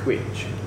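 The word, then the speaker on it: cwtch: It's Quich. – Quich. It's spelled C-W-T-T-C.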